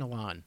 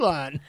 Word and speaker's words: Elon: Stop saying Alon. That makes it sound like he's a French whore. – But I prefer it's Elon.